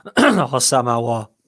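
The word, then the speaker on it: Hossam Aouar: How do you pronounce that? – Hossam OR.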